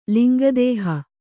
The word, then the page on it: ಲಿಂಗದಾಹ: linga dāha